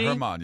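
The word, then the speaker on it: Germán: Is the G a soft G? – Yeah, Herman. Yeah.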